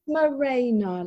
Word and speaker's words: Morena: It's Morena, like it's raining on the marina.